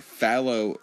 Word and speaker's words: Fallow: Follow Right. Let's make sure that's correctly pronounced. Follow Right. F-A-L-L-O-W, Follow, and then Right, as in the correct or the direction right.